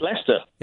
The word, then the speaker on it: Leicester: Can I just say, firstly, that you pronounce that beautifully? Because, you know, and I don't mean to sound condescending, you know that, but I'd expect you to say Leicester. – It is Leicester.